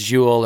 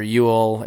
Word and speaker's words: Joel: And I'm like, oh, I guarantee it's not pronounced Joel. And sure enough, it's Jewel or Ewell Erickson Eck.